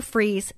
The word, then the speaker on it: Fries: Go to Sue Freeze, and that's spelled like fries, like French fries, .com.